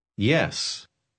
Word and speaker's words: yes: Yes. Ponga atención al sonido al principio de yes.